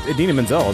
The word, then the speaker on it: Idina Menzel: It's Idina Menzel is the way I've always heard it pronounced.